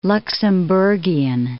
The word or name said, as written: Luxemburgian